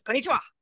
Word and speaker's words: konnichiwa: konnichiwa. Like, it's konnichiwa.